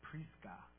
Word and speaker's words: Prisca: And I just saw, I'm not sure what your name means, but I know in Roman, I think it's Prisca is how it's said.